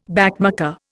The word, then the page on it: backmarker: Indian